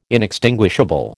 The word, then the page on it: inextinguishable: American English